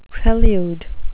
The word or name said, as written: prelude